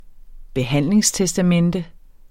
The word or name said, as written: behandlingstestamente